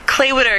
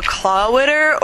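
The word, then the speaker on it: Clāwitter: It's saying Claywitter, because people go Clawitter or Claywater, and I'm like, not okay with that.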